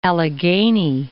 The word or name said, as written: Allegheny